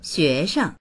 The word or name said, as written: xue2sheng